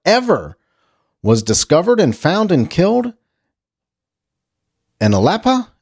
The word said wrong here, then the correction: Alapaha: Am I saying that right? In Alapa, Georgia. Alapaha, Georgia.